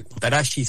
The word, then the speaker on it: Kutaragi: Y ahí un personaje en Kutaragi, fue, Kutaragi se debe pronunciar,